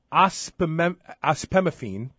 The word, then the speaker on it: Ospemifene: They now have a non-estrogen option called Osfina. Am I pronouncing that correctly? – Yeah, it's actually, its correct drug name is Ospemifene, which is very, which by the way, sounds like clomiphene because it's very similar to clomiphene in that it's a selective estrogen receptor modulator, a SERM.